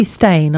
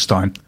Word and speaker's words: Steyn: You can find him at Mark Stein, S-T-E-Y-N, online. Could be Stein. I never really know how to say it. – It's pronounced Stein. – Stein, and he's a really, really lovely man, and I do believe that he really cares.